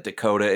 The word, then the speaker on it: Decoda: By the way, I just want to note right now that Dakota isn't spelled like North or South Dakota. It's spelled D-E-C-O-D-A. – Dakota. – Like a decoder or something.